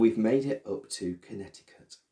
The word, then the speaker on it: Connecticut: Well, it's got that C in the middle of it. I didn't expect Connecticut. – Oh, yeah. Connecticut. – So it starts with the word connect. So, but it's pronounced Connecticut.